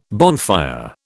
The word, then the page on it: bonfire: British English